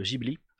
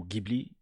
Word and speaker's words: Ghibli: Mais euh, j'étais très mécontent récemment d'un programme sur France Culture qui analysait les films de Ghibli, ou Ghibli, selon ce que vous préférez comme prononciation. Et donc,